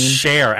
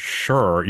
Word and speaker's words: Cher: And I texted you and I was like, are you pronouncing it sure? And you're like, no, I'm saying Cher.